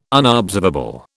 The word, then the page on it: unobservable: British English